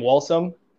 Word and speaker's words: Walsom: T-A-N-N-E-R. And my last name, Walsum. W-A-L-S-O-M.